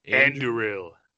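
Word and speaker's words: Andúril: but Anduril? – I'll correct you. Anduril. – Anduril.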